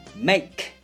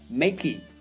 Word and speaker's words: make: make，你不会听到别人把这个词念作 makey，因为这里的 e 按照发声的原则的话呢，它不发音。